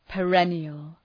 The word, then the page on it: perennial: pə’renıəl